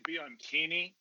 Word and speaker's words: Bianchini: I always say Bianchini, but you could say it however you want, man.